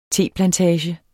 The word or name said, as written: teplantage